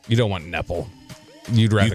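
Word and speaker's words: Knueppel: You could be taking Con Knepel from Carol from Duke. – You pronounce the K? – They do.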